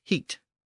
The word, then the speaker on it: hit: The neutral version was hit, pronounced heat.